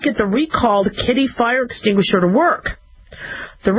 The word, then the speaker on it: Kidde: Kidde. Or do they pronounce it kid or kide? It's K-I-D-D-E. I look at it as kitty. Kidde, okay? Like, where are the kitties type of thing?